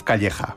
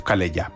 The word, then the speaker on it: Calleja: Y luego también, bueno, pues hay un tenor muy célebre en el mundo, que es, yo lo voy a pronunciar a la española porque creo que tiene ancestros de nuestro país, que es Josef Calleja, aunque creo que ahí lo dicen Josef Calella, pero Calella no, Calella está en otro sitio.